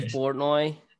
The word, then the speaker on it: Portnoy: Or what, how do you say his last name again? – Dave Portnoy. – Or Portnoy, yeah, that's how you, okay.